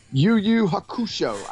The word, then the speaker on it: Yu Yu Hakusho: Yu Yu Hakusho.